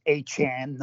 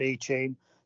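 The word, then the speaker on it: Achane: It's Achan, not Achan.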